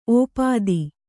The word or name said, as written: ಒಪ್ಪೊತ್ತು